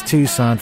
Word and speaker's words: Tucson: Let me tell you, Resonars from Tucson, Arizona. That's Tucson for you people pronouncing it unphonetically.